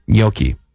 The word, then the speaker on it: gnocchi: It's not gnocchi. – Yeah. – Gnocchi. It's like gnocchi. – That's what the gnocchi is for. – Come over. – Gnocchi. – Okay, wait. Say it correctly one more time. – Gnocchi. – Gnocchi. – Gnocchi. – It's closer to gnocchi.